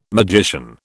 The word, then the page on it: magician: British English